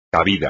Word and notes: cabida: espagnol

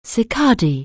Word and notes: cicada: anglais

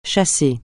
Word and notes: chasser: français

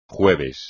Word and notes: jueves: Spanish

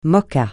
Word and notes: moka: francais

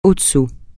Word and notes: au-dessous: francais

